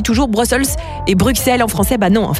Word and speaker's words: Bruxelles: Non, on ne dit définitivement pas Bruxelles parce que ça vient du latin, du romain. Et donc ça ne se prononce pas X mais S. Donc c'est bien Bruxelles une bonne fois pour toutes.